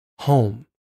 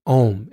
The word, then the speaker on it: Homme: And she invited me to a special online salon that she called Home. Or is it Om? It's spelled the French way. It was H-O-M-M-E. So I'm like, oh, Om, because like meditation. But it was like home because we're coming home.